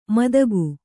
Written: ಮಾದಗ

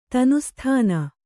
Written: ತನುಸ್ಥಾನ

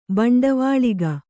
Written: ಬಂಡವಾಳಿಗ

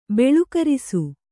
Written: ಬಲುಕರಿಸು

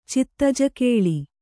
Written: ಚಿತ್ತಜಕೇಳಿ

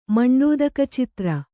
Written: ಮಂಡೋದಕಚಿತ್ರ

